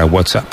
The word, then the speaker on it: WhatsApp: Tú, WhatsApp. No, peor aún, con acento en la primera.